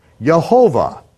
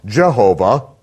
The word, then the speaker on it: Yehovah: Yehovah. Yehovah, which of course in English over in America they would mispronounce as Jehovah, because we would naturally, Americans just always mispronounce the letter J.